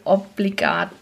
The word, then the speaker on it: Obligat: Hallo und herzlich willkommen zu einer neuen Folge Obligat, dem prähospitalen Podcast.